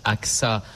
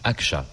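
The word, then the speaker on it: Ağca: Alors, ça se prononce Aksa en français, mais en turc, Akça, puisqu'il était turc.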